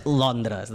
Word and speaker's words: Londres: Londres. Londres. – Ben pronunciat, en català és Londres, d'acord?